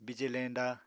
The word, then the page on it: bije leñda: Apatani